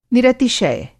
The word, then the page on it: निरतिशय: Hindi